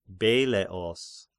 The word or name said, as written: Beleos